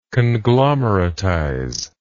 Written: conglomeratization

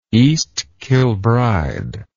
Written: East Kilbride

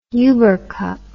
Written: ubercup

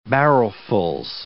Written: barrelfuls

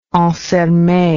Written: ansermet

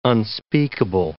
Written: unspeakable

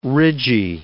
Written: ridgy

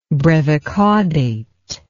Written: brevicaudate

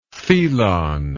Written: thelon

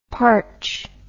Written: partch

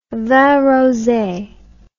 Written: vin rose

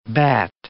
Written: batt

